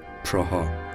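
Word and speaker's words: Praha: به این شهر در تلفظ خود چکیا پراها گفته میشه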